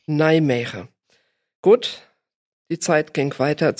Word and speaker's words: Nijmegen: Nijmegen. – Nijmegen. Oder Nimwegen auf Deutsch. In Deutschland sagt man, glaube ich, eigentlich Nimwegen.